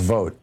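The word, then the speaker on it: Vought: The weekend, and also over the weekend about the Russell Bott about who wrote the blueprint of the Project 2025. – Yeah, it's pronounced vote, but yes, absolutely, Johan.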